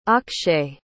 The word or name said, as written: akshay